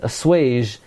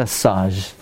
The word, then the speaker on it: assuage: In order to assuage, is that how you pronounce it? Assuage the Das of the husband. Right? Not assage. Assuage the Das of the husband,